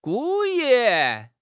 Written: gu4 yi4

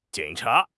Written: jing3 cha2

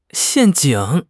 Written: 陷阱

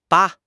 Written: ba5

